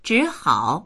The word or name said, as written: zhi3hao3